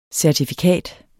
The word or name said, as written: CERTIFIKATS